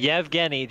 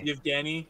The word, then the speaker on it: Evgeny: Yevgeny. Just say Yevgeny. Put Y. – Yevgeny. – Okay. Yeah, even though there's no why, it's Evgeny.